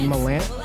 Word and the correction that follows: Mandalorian: Why can't I say that? Mandalorian. I forgot how to say it for a second.